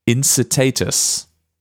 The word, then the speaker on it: Incitatus: Incitatus. – Incitatus.